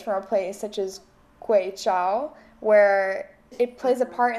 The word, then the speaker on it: Guizhou: I'm not exactly sure of the pronunciation. guizhou. Gui, uh, Guizhou. Guizhou. Okay, Guizhou. Guizhou, China. Yeah.